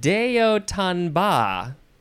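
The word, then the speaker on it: d'haut en bas: D-E, second word, H-A-U-T, which is just pronounced O. Third word, E-N. And then the fourth word is B-A-S, but yes, it is pronounced ba. Deotanba.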